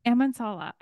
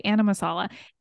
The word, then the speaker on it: Amansala: Amansala. – I always want to say the A-N first. I want to say Anamasala.